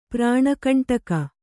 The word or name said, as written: ಪ್ರಾಣಕಂಟಕ